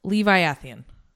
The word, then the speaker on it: Leviathan: It sounds, and she literally says it, and it sounds like Levi and Athian. So it's Levi Athian.